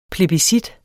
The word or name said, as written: PLEBISCITTET